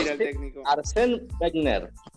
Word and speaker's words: Wenger: ¿Cómo se dice? ¿Alguien lo sabe? Arsène Werner. Wenger. Wenger.